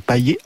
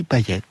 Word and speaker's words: Payet: Or, si l'on dit Payet, eh bien, c'est tout simplement parce que le joueur est natif de Saint-Pierre sur l'île de la Réunion. Et là-bas, donc, Payet se prononce Payet.